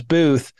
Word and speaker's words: Boothe: And he said, is it Boothe or Boothe? And I was like, no, it's Boothe. We just added an E when John Wilkes Booth killed Abraham Lincoln.